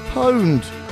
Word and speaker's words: pwned: It is pronounced po, as in fuck the police, and as in minus a, pwned.